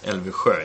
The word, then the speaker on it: Elvisjö: It's actually pronounced Elvisjö in Swedish, but I know that's a little bit difficult for you.